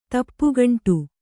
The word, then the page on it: ತಪ್ಪುಗಂಟು: tappu gaṇṭu